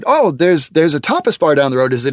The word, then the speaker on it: tapas: And we had seen a tapas bar. Now, you guys say tapas, but I didn't know that. We say tapas because that's how it's correctly pronounced. If you do it with Spanish, tapas.